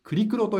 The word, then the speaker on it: クリクロ: ブラックスタウトはあクリクロと読むんですね。